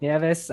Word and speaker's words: Nieves: It's Nieves, by the way, Nieves. But don't worry about it. Sorry. I know that it's super hard to pronounce. But yeah, my name is Patrick Nieves.